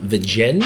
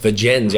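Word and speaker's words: Vergennes: Today we're in glorious, is it Vigen or Vigens? – Vigens, you actually pronounce the S. I thought it was like French, like Virgin. Not quite.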